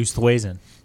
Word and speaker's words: Oosthuizen: I'm going to go with Louis Oosthuizen. Mostly because his name's fucking cool. – I think it's actually Oosthuizen. – Whatever. – Oosthuizen. – It's spelled O-O-S-T-H-U-I-Z-E-N. That's just a cool name.